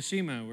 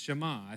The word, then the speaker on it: Shema: We call it the Shema. We're mispronouncing it. It's Shema, I think, is what you'd say.